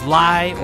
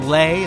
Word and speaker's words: Lai: Before that, we had four tracks from Francis Lai, or Lay. It's spelled L-A-I. Could be me Lai, could be Lay. I prefer to say Lay.